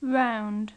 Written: round

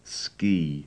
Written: ski

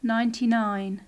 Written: ninety-nine

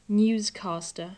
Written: newscaster

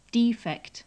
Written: defect

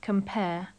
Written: compare

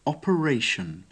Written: operation

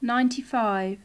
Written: ninety-five

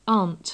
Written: aunt

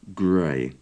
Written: grey

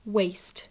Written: waste

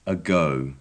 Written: ago

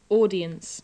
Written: audience

